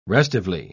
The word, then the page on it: restively: British English